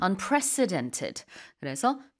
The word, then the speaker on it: unprecedented: Unprec 이게 스 가운데 C가 약간 강하게 발음이 되어야 돼요. Unprecedented 하면 안 되고, 그러면은 President처럼 들리잖아요. 대통령 아니고요. Unprecedented. 그래서 전례가 없다.